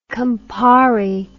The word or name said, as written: campari